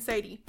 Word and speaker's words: Sadie: No S at the end, Sadie.